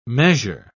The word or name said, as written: measure